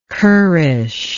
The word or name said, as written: currishness